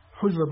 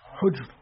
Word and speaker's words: حجر: طيب حجر ابن عدي، حجر عشان بعض ينطقها حجر في غلط، حجر ابن عدي، هناك اسم حجر موجود لكن ده اسمه حجر، حجر ابن عدي كندي، هو البخاري عده من التابعين، والصحيح أنه من الصحابة رضوان الله عليه.